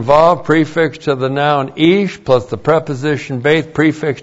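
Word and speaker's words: Ish (איש): And then we have ish. Aleph. Long I-S-H, Ish.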